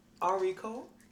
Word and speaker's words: Arie.Cole: And today we have Arie.Cole.